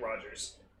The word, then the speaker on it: Rodgers: All So, there is a musician and composer named Dave Rodinger? – Dave Rogers. – But there's a D in there. – Yeah, Rogers. Rodgers. You just pronounce it like Rogers. – Okay. So, D silent. Hopefully.